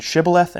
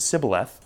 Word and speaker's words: shibboleth: You'll notice the difference between shibboleth and sibboleth is that SH sound. That's it.